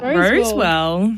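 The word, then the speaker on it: Rosewall: How do I say that? – Roswell. – Roswell? – Like, even the way you said Ken was weird. – What, Ken? How else can you say Ken Roswell? – Sir Ken Roswell.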